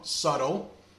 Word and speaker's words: subtle: Subtle. It has a silent B.